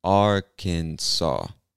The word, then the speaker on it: Arkansas: Arkansas.